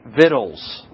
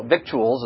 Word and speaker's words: victuals: Well, he commands them that they are to take victuals. The word victuals is what it looks like, but it's actually pronounced victuals.